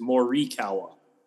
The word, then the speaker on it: Morikawa: It is Morikawa. Uh, it's the I is an E. It's Morikawa, not Morikawa. So, well, are you changing?